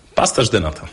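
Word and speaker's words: pastel de nata: Pasterz de nata, dokładnie. Mhm. – Wszystkie S na końcu w języku portugalskim tak, wymawiamy tak, jak tak. S.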